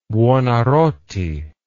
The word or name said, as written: buonarroti